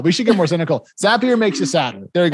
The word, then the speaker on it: Zapier: The CEO taught me a mnemonic about how to pronounce Zapier, Zapier. And I, oh yeah, they did. Happier makes you happier.